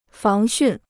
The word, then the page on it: 防汛: fáng xùn